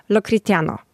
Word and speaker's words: Los Cristianos: Na przykład S prawie się nie wymawia, jeżeli jest na końcu wyrazu. Jest miejscowość Los Cristianos, to oni powiedzą Lo Cristiano.